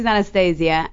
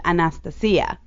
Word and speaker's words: Anastasia: Well, it's actually pretty funny because, so my name is Anastasia, and but that's the English way of pronouncing it. The right. Greek way is Anastasia.